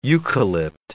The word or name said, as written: eucalypt